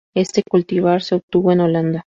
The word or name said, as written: Holanda